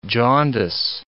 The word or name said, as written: jaundice